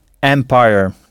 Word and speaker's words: empire: Alors, le mot pour empire est le même qu'en français, mais prononcé empire.